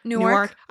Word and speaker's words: Newark: How did you say that word again earlier? Newark? – Yep, Newark.